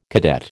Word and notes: cadet: American English

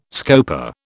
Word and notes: scopa: British English

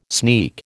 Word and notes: sneak: American English

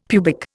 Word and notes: pubic: Indian

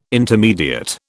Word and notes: intermediate: British English

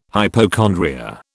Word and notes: hypochondria: British English

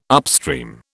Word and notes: upstream: British English

